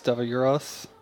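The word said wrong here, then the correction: Davaulus: Davalis. – Davalis.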